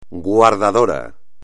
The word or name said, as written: guardador